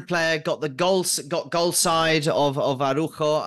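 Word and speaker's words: Araújo: It was the red card, Araujo, or as they pronounce it here, Araujo. I think it's easier, so they just cut out the middle A.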